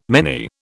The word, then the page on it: many: British English